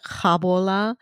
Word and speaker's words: Gabola: So this, it's spelled Gabola, but they pronounce it Khabola Church.